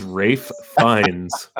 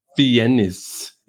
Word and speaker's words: Fiennes: Ralph Fiennes. Yeah, Ralph Fiennes. It's probably some French ass shit, you know. Angela Bassett, Juliette Lewis. Well, oh, it's his last name's Welsh. Fiennes.